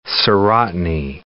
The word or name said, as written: serotiny